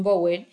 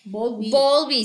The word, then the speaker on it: Bowlby: Y bueno, justo vamos a hablar de cuatro tipos de apegos que nos eh, clasificó eh, John Bowen. – Bowlby.